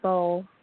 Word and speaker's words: Bau: And his name is Bo, right? – Yes. We spell it like beautiful, B-A-U.